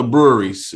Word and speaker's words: Bruery: actually it's actually called the Breweries, except it's spelled B R U E R Y. I'm sorry, E Y E R Y.